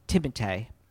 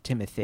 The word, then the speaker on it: Timothée: Well, it's French. It's actually Timothée, but most people just call him Timothy.